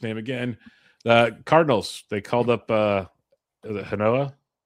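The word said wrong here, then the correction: Genao: Guardian, sorry, Angel Ganoa, right? – Angel Ganoa, yep. – Ganoa, god damn, so close.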